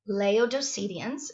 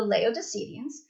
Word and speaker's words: Laodiceans: Laodiceans is actually how you pronounce it, okay? The Laodiceans.